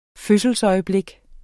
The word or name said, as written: fødselsøjeblik